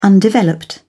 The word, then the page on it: undeveloped: British English